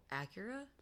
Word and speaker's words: Acura: Is it an Acura or an Asura? – Mm. It's A C U R A. It's a vehicle. – Acura? – Acura. Wow, I fucked up hard.